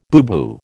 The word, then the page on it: booboo: American English